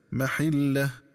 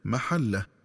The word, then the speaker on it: محله: وكذلك ينبغي التنبه للنطق الصحيح للفظ محله، فيقرأ بكسر الحاء هكذا محله، ومن الخطأ قراءته بفتح الحاء هكذا محله.